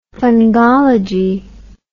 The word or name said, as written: fungology